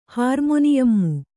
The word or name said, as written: ಹಾರ್ಮೊನಿಯಮ್ಮು